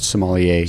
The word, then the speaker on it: sommelier: American Sommelier. – Sommelier. – Yeah, Association. – Sommelier.